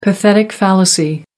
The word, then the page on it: pathetic fallacy: American English